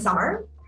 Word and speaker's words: Samar: My name is Samar, pronounced, spelled differently, pronounced like the season.